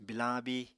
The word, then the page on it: bilañ abíi: Apatani